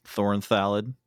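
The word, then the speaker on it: Thorn Thallid: Thorn Thalid. Whoa, I just said that correctly, but saying Thorn Thalid makes you think that you have a lisp.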